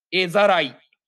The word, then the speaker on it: 絵ざらい: これで絵ざらいって読むんだ。絵ざらい。へはい、絵をさらうので絵ざらいです。